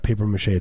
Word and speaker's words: papier-mâché: Yeah, that's how you pronounce it. It's not papier-mâché, it's French. Papier-mâché.